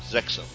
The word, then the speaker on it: Zexal: Zexal.